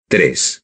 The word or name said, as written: tres